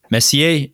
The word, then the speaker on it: Messier: Messier sounds right.